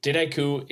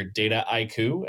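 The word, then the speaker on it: Dataiku: So there's a lot of different pronunciations, especially since we're such a global company, but the word itself is a portmanteau. So a mashup of data and haiku. Right. And haiku is a Japanese poem, for those who aren't familiar with it. So that's the biggest is in the US, you tend to hear DataIQ as sort of 4 different syllables.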